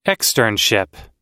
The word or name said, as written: externship